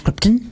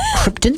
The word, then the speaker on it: Krypton: Can't do this on Krypton. – It's pronounced Krypton. Krypton.